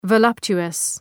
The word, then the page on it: voluptuous: və’lʌptʃʋəs